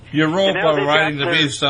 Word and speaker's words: Europa: Now, a lot of your listeners may not know this, but Europe comes from, it's a mispronunciation, it really should be pronounced Europa. – Europa.